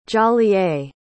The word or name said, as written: jolliet